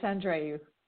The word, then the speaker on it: Andreou: Her name is Katerina, and is it Andreaou? Is that how you say your last name? – It is. Hi there. It's Andreaou.